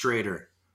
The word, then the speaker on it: Stroeder: It's written Schroeder, but way back in the day, there used to be two dots on the O, so it's pronounced Strader.